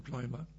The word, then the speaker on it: Pneuma: Geist ist Pneuma oder deutsch ausgesprochen Pneuma.